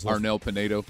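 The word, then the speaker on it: Arnel Pineda: Before Anado, whatever his name is. – Arnel Pinedo.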